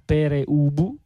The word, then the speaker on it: Pere Ubu: ci arriva un messaggio che dice: il vero punk pronuncia Pere Ubu come è scritto.